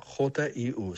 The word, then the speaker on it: HU: La palabra Hugh se escribe HU, se pronuncia JIU, suena así.